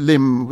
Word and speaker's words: limn: I remember learning the word from Goldsmith, L-I-M-N, which is pronounced probably limb without limb. – N at the end.